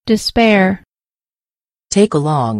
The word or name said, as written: despair